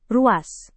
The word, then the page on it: ruas: indonesian